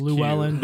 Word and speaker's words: Llewellyn: That's and Desmond Llewellyn is Llewellyn.